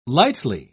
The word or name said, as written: lightly